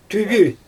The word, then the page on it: tyby: Xyzyl